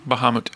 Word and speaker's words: Bahamut: Bahamut.